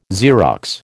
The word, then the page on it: Xerox: American English